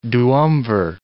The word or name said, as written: duumvir